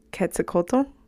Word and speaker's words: Quetzalcoatl: And curious on how you see that fitting in, if it fits in with I know there's the Quetzalcoatl. – Mm-hmm. Yeah. – Did I say that right? Mm-hmm. Quetzalcoatl feathered serpent.